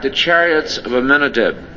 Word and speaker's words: Amminadab: Looking at that word Amminadab, here it's pronounced Amminadab.